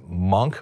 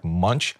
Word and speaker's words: Munch: they pronounce it Munch, but it looks like Munch.